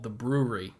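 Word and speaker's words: Bruery: There's a microbrew, I don't know, 10, 15 minutes away from me called The Brewery over in Placentia, California. Not spelled like B-R-E-W. It's B-R-U-E-R-Y, The Brewery.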